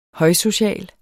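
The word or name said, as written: højsocial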